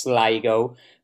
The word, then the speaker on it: Sligo: So it is pronounced Sligo.